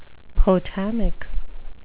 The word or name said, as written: potamic